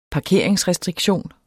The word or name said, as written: parkeringsrestriktion